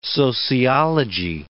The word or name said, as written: sociology